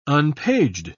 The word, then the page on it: unpaged: British English